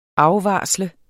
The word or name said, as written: afvarsle